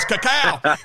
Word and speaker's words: cacao: Lily always goes when I say cacao. She goes, that's not how you pronounce it. I said it is. Luke told me it is. It's cacao.